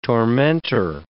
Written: tormentor

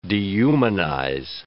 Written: dehumanize

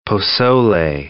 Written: posole

